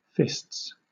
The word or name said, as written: fists